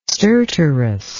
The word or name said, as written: stertorous